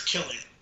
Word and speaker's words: Cillian: So, I'm pretty sure it's Cillian.